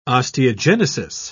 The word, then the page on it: osteogenesis: British English